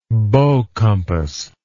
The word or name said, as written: bow compass